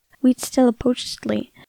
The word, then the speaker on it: Huitzilopochtli: It was a celebration of, and I'm going to pronounce this, Huitzilopochtli.